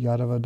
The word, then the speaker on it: Yadava: Yadava dynasty, with a long mark, Yadava dynasty, long A.